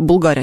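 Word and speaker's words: Bulgari: Знаешь, кстати, что Булгари, а не Булгари, на самом деле? – Нет, не знала, сейчас первый раз. – Итальянская марка, поэтому ударение на предпоследний слог.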